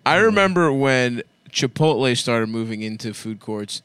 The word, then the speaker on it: Chipotle: They swapped the letters. – Chipotle. – But it's so fucking easy to say Chipotle.